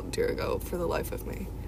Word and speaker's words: Dirigo: Anyway, and then the race that we had was called the Dirigo Sprints. – Is that how you pronounce it? I can never spell it right for some reason. – D-R-I-G-O. – D-R-I-G-O. – Drigo? – No, D-I-R-I-G-O.